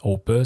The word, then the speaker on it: Hoppe: Donc, on, apparemment que ça se prononce comme ça parce que, en fait, bien que c'est un, économiste et un philosophe américain, Hoppe, c'est un. Euh, il est d'origine allemande.